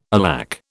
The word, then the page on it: alack: British English